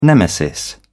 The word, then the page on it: nemesis: British English